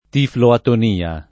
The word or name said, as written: tifloatonia